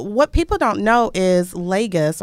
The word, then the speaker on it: Lagos: So it's a Portuguese name, so it could be Lagos. However, it was British ruled, so there you go, the long A, Lagos.